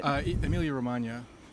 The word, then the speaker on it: Emilia-Romagna: Emilia-Romagna.